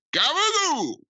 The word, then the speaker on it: gabagool: It's one of the many Italian lunch meats, and it's capicola is how you would Americanly pronounce it, but somehow that gets turned into gabagool.